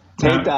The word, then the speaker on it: Tita: We actually pronounce it Tita, but Teta, I think, is the best way to pronounce it for the real Italian enunciation.